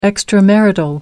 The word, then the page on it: extramarital: American English